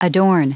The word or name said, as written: adorn